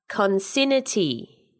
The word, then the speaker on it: concinnity: It is consignity. – I don't know what that means. – Well, it's spelt C-O-N-C-I-N-N-I-T-Y. Consignity.